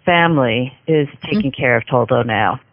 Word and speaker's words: Toldo: Now, this is the cat's name is it pronounced Toldo? – Toldo, yes. – T-O-L-D-O.